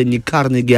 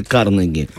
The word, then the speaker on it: Карнеги: Да, вставил своих 5 копеек, типа, вообще-то не Карнеги, а Карнеги, а на самом деле Карнеги.